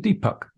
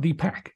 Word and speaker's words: Deepak: Yeah, say my name's Deepak, not Deepak. Yeah, okay, yeah, because we have both those vowels. We have Puck, Puck and Pak are both part of our inventory of sounds.